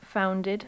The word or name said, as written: founded